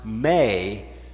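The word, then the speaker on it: may (μή): the negative particle pronounced may. It's only a two-letter word, but when we have that word, that is telling us that the answer is no.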